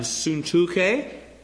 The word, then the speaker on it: Syntyche: Now, this friction was due probably to internal conflicts, like that between Euodia and Syntyche.